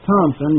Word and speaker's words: Thomson: So the translator, Charles Thompson, and by the way, that is spelled T-H-O-M-S-O-N. The letter P is not in his last name. So the translator, Charles Thompson, used interest and usury interchangeably.